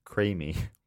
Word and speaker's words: creemee: In my home state of Vermont in the US of A, we have something called a creamy, pronounced creamy. Well, I nailed it. It's written CREEMEE.